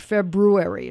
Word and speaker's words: February: You forgot the R. February. It's not February.